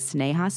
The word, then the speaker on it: Sneha: Now, I want to say, Britt, starting out, I've heard some people pronounce her name Sneha, but I knew somebody whose name was spelled exactly the same way, and she pronounced it Sneha, so I'm going to pronounce it Sneha.